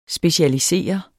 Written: specialisere

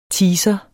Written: teaser

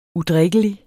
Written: udrikkelig

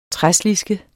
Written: træsliske

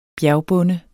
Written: bjergbonde

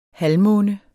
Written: halvmåne

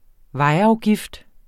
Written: vejafgift

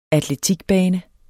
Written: atletikbane

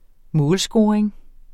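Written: målscoring

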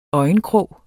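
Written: ØJENKROG

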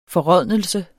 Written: forrådnelse

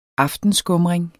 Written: aftenskumring

